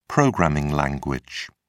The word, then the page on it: programming language: British English